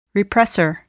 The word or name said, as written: repressor